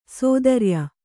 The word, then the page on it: ಸ್ಥಿರಾಯು: sthirāyu